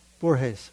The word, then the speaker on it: Borges: Am I saying it right? Huh? Borges. Okay, thank you.